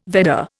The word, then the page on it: Vedda: Indian